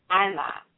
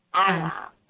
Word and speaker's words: Aina: It's P-H-U-L-A, Fula. The seventh word is mirror, and mirror is spelled A-I-N-A, and it's pronounced Anna. Anna. So it's like Anna, but it's a little bit higher.